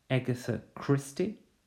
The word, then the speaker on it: Agatha Christie: It's Agatha Christie. Agatha Christie, with a stress on the first syllable.